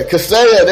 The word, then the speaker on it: Kaseya: Yeah, Kaseya, there you go.